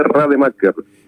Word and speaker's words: Raedemaeker: Gabriel de Rademacher.